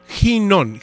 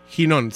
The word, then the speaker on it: Ginón: No, Ginón, G. – Ginón. – Ginón, sí, es, no es con la U, nada más así directo. Ginón, con acento en la O.